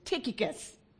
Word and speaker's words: Tychicus: And his name is Tychicus. Yeah, I know there are many spellings and many ways of pronouncing it, but hey, Google says it's Tychicus.